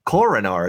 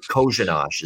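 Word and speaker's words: Kořenář: Kojanosh is how you say it. So there you go. So you wouldn't have known that. You look at it, it's Korenar. And somehow it's pronounced Kojanosh.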